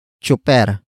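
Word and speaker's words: tsuper: Some Tagalog speakers pronounce it as chuper, chuper, chuper.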